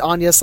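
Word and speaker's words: Agnès: I think she pronounces it Agnes, like, in movies. Like, the French way, it's probably proper.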